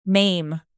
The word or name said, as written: maim